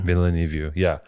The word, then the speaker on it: Villeneuve: Villeneuve, yeah, which is great.